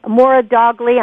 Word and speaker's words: Moradoghli: Rebecca Moradoghli, I'm probably murdering your name, but I told you I'd have fun with it.